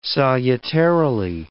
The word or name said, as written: salutarily